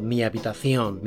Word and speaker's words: Mi habitación: Of course you know what mi habitación means. Yes, my room. Notice how it's pronounced. Mi habitación. It's not mi habitación, okay? The H is silent.